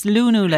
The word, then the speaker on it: lunula: Is that Lunula? – I think it's pronounced L-U-N-U-L-A, and I think you pronounce the U's as Lunula.